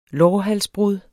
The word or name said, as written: lårhalsbrud